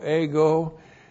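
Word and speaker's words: ego (ἐγώ): E-G, long O, ego.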